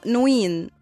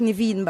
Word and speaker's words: نيفين: في هنا عندي نيفين، ده اسم فارسي، كان اسمه نوين، ولكن الفرس كانوا بينطقوا الواو في، فطبعا العرب كانوا بيسمعوها نيفين، فخلاص هي بقت نيفين بقى، وهي معناها ذو المقام العالي الرفيع.